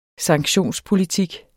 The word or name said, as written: sanktionspolitik